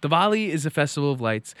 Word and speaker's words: Diwali: First off, it's Diwali. I mean, that's on us. I get it. It's spelled D-I-W-A-L-I. But for some reason, Indians are just goofy and it's pronounced Diwali.